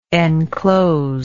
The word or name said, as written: encloser